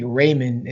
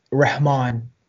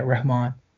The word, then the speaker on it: Rehman: So in school, I mean, they call me Raymond in school, but it's Rehman with an H, like the sound like yeah, Rehman.